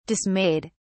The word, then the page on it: dismayed: British English